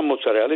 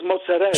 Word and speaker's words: mozzarella: It's not mozzarella. It's mozzarella.